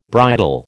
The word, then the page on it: bridal: American English